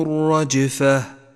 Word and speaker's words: الرجفة: الرجفة أخذتهم الرجفة ولاحظ أن اللام لونت باللون الرمادي لأنها لام شمسية تكتب ولا تلفظ